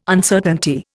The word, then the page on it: Uncertainty: Indian